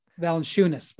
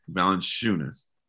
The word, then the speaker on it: Valanciunas: One, I like, and I always butcher his name, Valanciunas, but that's okay. Jonas. – See, Valanciunas.